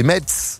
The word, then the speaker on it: Metz: C'est comme Metz. Il y a un T. Moi, au début, je me disais Metz. – Ah, disais vraiment Metz ? – Bah, je disais Metz, moi, bien sûr. Et après, on prononce pas le T.